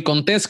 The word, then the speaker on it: Contés: Mi nombre es Miguel Contés con acento en la E y nos vemos la próxima.